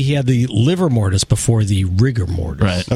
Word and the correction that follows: livor: Is it pronounced liver or livor? Because it's spelled livor. And livor. – Livor, livor. – It doesn't matter. – Yeah. You're not a local. It's livor. Livor.